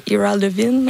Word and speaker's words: Ira Levin: Ira Levin.